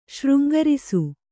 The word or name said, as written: ಶೃಂಗರಿಸು